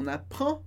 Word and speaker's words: n'apprend: O que muda bastante é que a pronúncia termina no an, quando o verbo está conjugado. On n'apprend pas, a gente não aprende, ou aprende. On n'apprend pas, sem pronunciar o D.